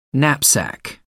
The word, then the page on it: knapsack: American English